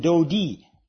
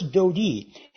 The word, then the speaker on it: Dodi: And the punctuation that is provided in most Hebrew manuscripts implies what we know as the letter I, and is pronounced an E sound in Hebrew. So it's Dodi in verse 9 here.